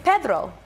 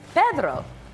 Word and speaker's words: Pedro: Like, it's Pedro. That's how you say his name. Pedro.